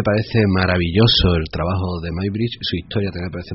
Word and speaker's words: Muybridge: o Muybridge, que es como se escribe, según que se dice Muybridge, y que fue un señor que empezó a determinar, gracias a la fotografía, a determinar cómo realmente se movían los seres, ¿no?